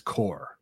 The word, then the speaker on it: corps: I think that's what it was, because to my knowledge, corpse, c-o-r-p-s, like receiving, that's core, it's receiving core, but that's just spelled like corpse, right? – I believe so.